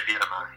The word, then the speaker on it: Beira-Mar: Is it pronounced Beira-Mar? – Yeah, Beira-Mar. Yep.